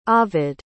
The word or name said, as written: ovid